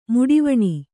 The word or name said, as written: ಮುಡಿವಣಿ